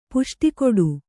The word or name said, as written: ಪುಷ್ಟಿಕೊಡು